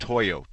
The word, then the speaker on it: Toyo: And I just heard you talking about out of Tolo or whatever. How do you spell that? – Toyo. T-O-Y-O.